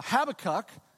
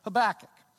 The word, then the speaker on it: Habakkuk: Uh, some people will pronounce it uh, uh Habakkuk, and then some people will pronounce it Habakkuk.